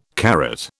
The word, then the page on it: carat: British English